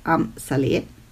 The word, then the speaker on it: Amsale: Kleiner Fun Fact: Das Label hatte Neil Brown mit seiner verstorbenen Frau Am Saleh Abera gegründet. Manche sagen auch Amsale, aber ich glaube, die korrekte Aussprache äh, ist laut Wikipedia Am Saleh.